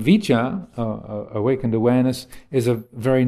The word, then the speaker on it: vijja: So in that respect, I like to use the word vicha, the Pali word vicha, V-I-J-J-A, long A at the end, vicha, as, say, representative of that quality of awakened awareness.